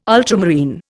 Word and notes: ultramarine: Indian

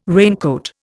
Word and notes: raincoat: Indian

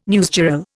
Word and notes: newsgirl: Indian

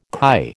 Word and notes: kai: American English

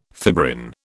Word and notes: fibrin: British English

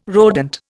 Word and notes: rodent: Indian

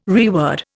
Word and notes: reword: Indian

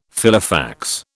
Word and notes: Filofax: British English

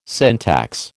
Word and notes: syntax: American English

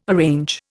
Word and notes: arrange: Indian

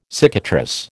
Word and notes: cicatrice: American English

